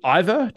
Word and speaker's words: Iver: Iver, did I pronounce that right? – Yes. It's funny that when when we have two people in the band with almost identical names, it doesn't help that in Ivar's pronunciation, he doesn't really make a difference between his name and mine. It sounds almost the same. So if it, either you say, I would say Iver in Norwegian, but Iver is also quite fine.